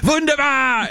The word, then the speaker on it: Wunderbar: So Curtis picked Wonder Bar, and I'm like, "You're not saying it right. It's Wunderbar!" And they both immediately reflexively yelled Wunderbar!